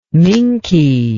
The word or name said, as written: minke